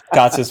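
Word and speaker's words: Kartsios: So spricht man es aus, Karzios, ne? – Genau, also ich kann meine Mutter noch mal fragen, aber Karzios war korrekt.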